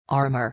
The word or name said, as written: armor